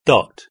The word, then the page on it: dot: British English